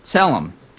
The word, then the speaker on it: Tselem: The best I can pronounce it is Tselem, T-S-E-L-E-M.